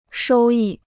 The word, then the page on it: 收益: shōu yì